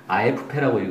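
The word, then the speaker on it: AFP: 아, 이게 사실은 아에프페라고 읽어야 되는 거 아시죠? 아, 아에프페. 네, 프랑스어니까.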